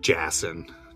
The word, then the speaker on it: Jassin: Is that Jason, J-A-S-O-N, or is that with a Y? – Oh boy, it's spelled Jason, J-A-S-S-I-N, if you must know. – But it's pronounced Jason.